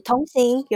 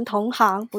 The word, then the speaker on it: 同行: 所以是同行，有些人会念成同行，不对哦，是同行。